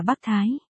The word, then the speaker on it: Bắc Thái: Cái gì mà Thái Bắc Đế Quân chứ? Ấy, không đúng, nên đọc là Bắc Thái.